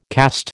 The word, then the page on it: cast: American English